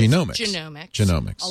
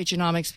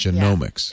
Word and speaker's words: genomics: The correct way to say it is like the human genome. It is genomics. – A lot of people say genomics because it's more like economics. – But genomics. – Yeah. It is the genomics age.